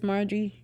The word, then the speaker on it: Marjorie: Marjorie? That's how you say it? – Her name is Marjorie.